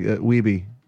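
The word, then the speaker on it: Wiebe: There's even a scene where, like, you know, fucking Walter Days in King of Kong is going, Steve Weeb, everybody. He's like, Weeby. It's Weeby. And then, like, they're like, oh, we've been saying it wrong the whole time.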